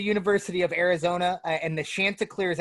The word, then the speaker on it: Chanticleers: And this is going to pique my attention to watch this game to see why Coastal Carolina, the Chandeliers, they're called. Or no, I'm probably pronouncing their name wrong. – Chanticleers. The only reason I know that, actually, really funny story.